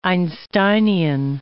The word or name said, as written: Einsteinian